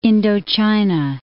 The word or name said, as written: Indochina